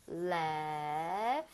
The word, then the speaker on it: laugh: Laugh. 그 같은데요? – GH는 F와 비슷하게 발음이 됩니다. 네.